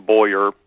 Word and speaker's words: Boire: Yes, I am Martin Boyer. Last name is spelled B-O-I-R-E,